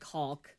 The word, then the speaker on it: caulk: And especially because I don't think that anyone, I mean, at least in my life, I've never pronounced it caulk. Like trying to make sure that the L is heard.